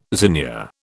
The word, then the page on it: zinnia: British English